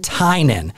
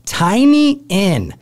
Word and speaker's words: Tynan: The local Gothamites pronounce it Tynan, but out-of-towners say Tiny Inn, and the Gothamites give them shit about it.